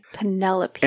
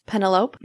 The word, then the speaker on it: Penelope: Penelope. – And I totally remember this one, reading it many years ago as a kid and pronouncing it like it looks. Like, hold on, let me guess. Penelope? – Yes.